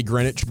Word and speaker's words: Greenwich: I think you say Greenwich, but it's spelled Greenwich.